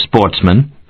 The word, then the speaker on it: sportsmen: who were sportsmen, a French word pronounced with the accent on the men.